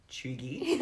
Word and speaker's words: cheugy: And that word is, I believe, pronounced chugy. – Oh, God. – And what Zara, it's spelled C-H-E-U-G-Y. – Yeah.